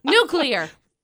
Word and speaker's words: nuclear: Nuclear. Always. – Nuclear. – No! Nuclear. Nuclear. Read it.